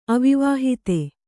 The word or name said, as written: ಅವಿವಾಹಿತ